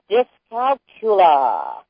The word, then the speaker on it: dyscalculia: Why not whip out a word, and let me, correct me, Marie, if I'm not pronouncing it correctly, dyscalculia. Doesn't that sound wonderful? – Yeah. – D-Y-S-C-A-L-C-U-L-I-A.